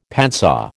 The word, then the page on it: padsaw: American English